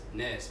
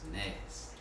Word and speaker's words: Ness: One thing I gotta say, bro, my name is Ness, bro, and I'm saucy. People really be saying my name like it's a word, like happiness, madness, sauciness.